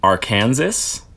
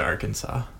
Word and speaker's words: Arkansas: I just like that Arkansas and Kansas, like, they are so similar in words. – Spelt the same. – Right, yeah. And yet they're pronounced completely differently.